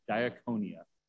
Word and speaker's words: diakonia: When it says they were being neglected in their daily distribution, this word for distribution is this first word, diakonia. It's spelled D-I-A-K-O-N-I-A.